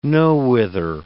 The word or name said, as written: nowhither